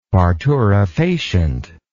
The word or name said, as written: parturifacient